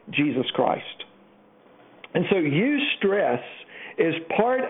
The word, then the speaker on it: eustress: Psychologists and health professionals sometimes call it eustress. And that's spelled E-U, stress. It's good stress, E-U, like where we get our word eulogy at a funeral.